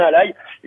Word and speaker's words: Ally: La console, c'est le ROG Ally A2LY, ça se prononce bien Ally, et surtout c'est leur première console qui est vachement intéressante.